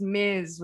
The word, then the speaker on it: Ms.: And it's often pronounced Ms. with a Z sound.